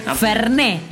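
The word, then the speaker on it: Fernet: Sí, Ferné. – Así, con acento en la E a pleno.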